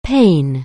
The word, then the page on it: pain: anglais